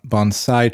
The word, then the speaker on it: bonsai: A, he makes a big point to make sure that you pronounce, pronounce it, right. correctly. Yeah, bonsai. Which yeah.